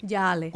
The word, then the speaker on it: Yale: De Yale. – Eso se escribe Yale. – Yale. – Como el candado.